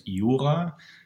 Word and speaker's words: Jura: Yeah, it's the region is the, I don't know how you pronounce it in English, but in German, it's Jura.